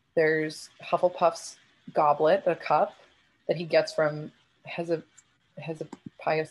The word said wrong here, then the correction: Hepzibah: Smith, whatever her name is. I always say it wrong, Hepzibah Smith.